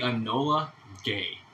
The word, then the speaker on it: Enola Gay: And the plane, the Enola Gay.